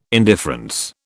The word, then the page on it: indifference: British English